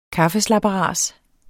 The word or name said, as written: kaffeslabberas